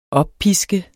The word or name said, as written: oppisket